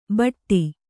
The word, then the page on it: ಭಟ್ಟಿ: kannada